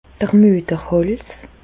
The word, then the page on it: d'r Müeterhàls: Bas Rhin; Reichshoffen